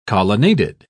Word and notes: colonnaded: British English

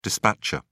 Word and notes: dispatcher: British English

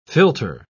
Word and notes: philtre: British English

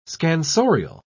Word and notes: scansorial: British English

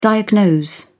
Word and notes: diagnose: British English

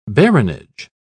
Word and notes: baronage: British English

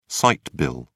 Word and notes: sight bill: British English

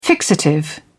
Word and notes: fixative: British English

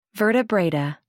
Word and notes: vertebrata: American English

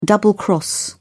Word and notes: double cross: British English

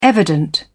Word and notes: evident: British English

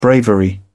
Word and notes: bravery: British English

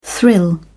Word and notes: thrill: British English

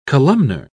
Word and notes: columnar: British English

